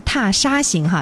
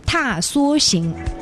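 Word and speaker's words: 踏莎行: 这里呢，我们要普及一个知识啊，很多人经常会把这个词牌名读成踏沙行哈，其实应该是踏梭行。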